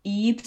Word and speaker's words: Ib: But a while ago I did play Eep, so it's spelled IB.